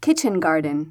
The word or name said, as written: kitchen garden